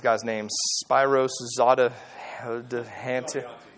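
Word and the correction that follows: Spiros Zodhiates: Spyros Zodiatis.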